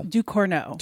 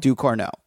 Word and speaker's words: Ducournau: Do you know how to pronounce her last name, or should I just muddle my way through? – Ducournau. – Ducournau. Okay, there you go.